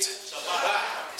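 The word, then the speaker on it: Shabach: It's called, it's spelled S-H-A-B-A-C-H. S-H-A-B-A-C-H. How do you say it? – Shabbat.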